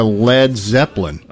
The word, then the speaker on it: Led Zeppelin: Drummer Keith Moon, when invited to play for a new band, thought the idea would go over like a Led Zeppelin. And they changed the spelling because they didn't want people to use the wrong pronunciation of lead.